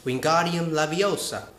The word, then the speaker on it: Wingardium Leviosa: Um, Wingardium Leviosa. You need to pronounce it like Hermione. Wingardium Leviosa.